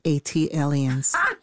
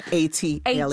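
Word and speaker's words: ATLiens: Tayari, just as Jill did for Philly, as an unofficial ambassador for Atlanta, an A-T-lians. – That is pronounced A-T-aliens. – Thank you. – Like Atlanta alien, A-T-alien. – A-T-alien, gotcha. And A-T-aliens, to quote Outkast.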